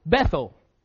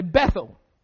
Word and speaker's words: Beth-El: But we would say Bethel. But probably a better way to pronounce it would be Beth-El, because that would give us a better understanding of what the word literally means.